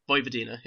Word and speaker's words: Vojvodina: I think it's pronounced Vojvodina or Vojvodina. – Vojvodina, yeah.